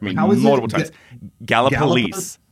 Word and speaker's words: Gallipolis: All right, it's pronounced Gallipolis. – Gallipolis. I knew because, that, like, because when I was there, I was pronouncing it wrong, and they all corrected me.